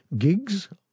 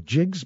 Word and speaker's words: gigues: It's a couple of Italian gigs or Jigs, but it's spelled G I G U E S.